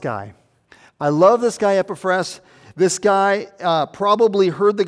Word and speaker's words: Epaphras: Epaphras is how they pronounce it in Greek. So Epaphras is what I'll go with.